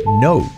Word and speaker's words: know: No. Saber. La K no se pronuncia. No. N-O-U.